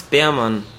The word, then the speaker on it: Beermann: Tobias Beermann. – Beermann. Wie schreibt man das? Mit Ä oder E? – Mit zwei E. Also B, Doppel E, R, M, A, Doppel N.